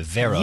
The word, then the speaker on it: Vero: Like very with an O. Vero. – Yeah, it's V-E-R-O.